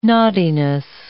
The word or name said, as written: naughtiness